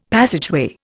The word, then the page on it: passageway: Indian